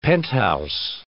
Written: penthouse

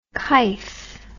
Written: kife

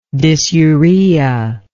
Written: dysuric

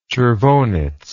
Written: chervonets

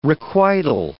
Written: requital

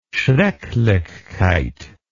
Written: schrecklichkeit

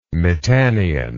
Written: mitannian

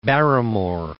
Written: Barrymore

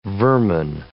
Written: vermin

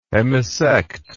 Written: hemisect

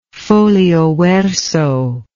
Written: folio verso

